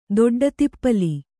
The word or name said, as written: ದೊಡ್ಡತಿಪ್ಪಲಿ